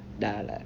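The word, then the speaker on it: Da Lat: Dalat. – Mm-hmm. – I mean, it's pronounced like Dallas, mm-hmm. – but they call it Dalat.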